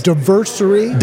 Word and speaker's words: Diversary: It's Diversary. – Diversary. – Like all right. – Anniversary.